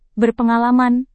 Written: berpengalaman

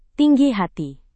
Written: tinggi hati

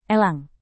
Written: elang

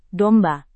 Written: domba

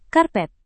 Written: karpet

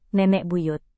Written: nenek buyut

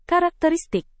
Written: karakteristik

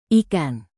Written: ikan

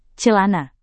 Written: celana

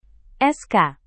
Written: sk

